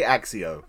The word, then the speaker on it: Accio: I say Accio. – I say Accio. I like the hard C.